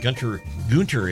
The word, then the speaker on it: Gunter: We'd like to thank the folks down there at Gunter is the proper way to pronounce it, I guess.